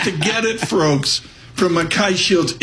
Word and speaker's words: MacKay Shields: Eric Kazatsky joins us with Mackay Shields. Am I pronouncing that right? – You got it. – Mackay nailed it.